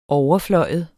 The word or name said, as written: overflyve